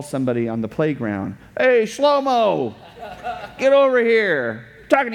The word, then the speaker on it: Shlomo: But as far as we can tell, the way his name was originally pronounced is Shlomo.